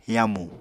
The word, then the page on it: yamu: Apatani